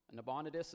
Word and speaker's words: Nabonidus: Nabonidus is how you pronounce his name.